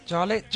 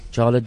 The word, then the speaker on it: Jarlette: but how do you say her surname? – Jarlet Joy. – Jarlet Joy. – Yes. – But the Jarlet is spelled J-A-R-L-E-T-T-E, and then... – Is that French? – Yes, sort of French, of a mixture of French and English.